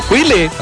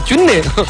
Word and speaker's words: 龟裂: 包括检查哈，有燃气的软管老米老化有没有菌裂啊，那个不叫龟裂啊，叫菌裂，每次都我晓得那个普通话的读音很普通。